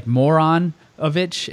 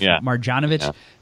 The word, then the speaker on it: Marjanovic: Which is like Moronovich instead of Marjanovic, and then Boban instead of Boban.